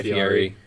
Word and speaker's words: Fieri: Fieri, I don't know how to say it. – Guy Fieri.